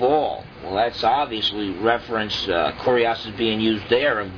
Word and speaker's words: kurios: Now the word there for Lord is kurios. K-U-R-I-O-S, the way we would spell it, kurios in the Greek.